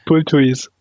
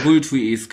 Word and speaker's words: Bulthuis: dann nach einem Kopfball von eurem Bulthus oder Bulthus, wie das ausgesprochen wird. – Bulthus. – Bulthuis, genau, den